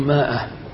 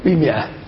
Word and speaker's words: مئة: وعندما أقول عند كثير أعني ذلك، فكثير صار يقول ماء وخمسماء بسبب هذه الألف، وهذا خطأ. الألف تكتب للتمييز لكن لا تنطق. والجرجاني قيد هذه العوامل في العنوان بكم؟ بمئة.